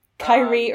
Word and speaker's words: Kyrie: if I'm saying that right, Kyrie Irving. – Kyrie um, Irving, yeah.